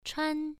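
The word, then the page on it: chuan: tone 1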